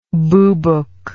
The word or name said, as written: boobook